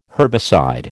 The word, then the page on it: herbicide: American English